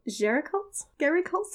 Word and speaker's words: Géricault: And he found the painter, the French romantic painter, Theodore Géricault? – Sounds good to me. – Say it like you spell it. – That's right.